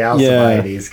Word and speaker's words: Alcibiades: But then it's so interesting to me that the whole thing ends, and I never pronounce his name correctly, but Alcibiades, is it a hard C? – Probably in Greek it's a hard C, and then we say Alcibiades because we're Alcibiades, like Frankish.